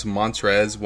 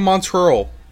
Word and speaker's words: Montrezl: This is a sidebar, but if I have to hear Dominique try and pronounce Montrezl one more time, I'm gonna like he he, he was calling him Montreal, Montreal Harrell.